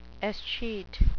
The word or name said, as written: escheat